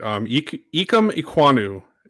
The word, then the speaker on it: Ikem Ekwonu: Ikem Ekwonu is how you say it.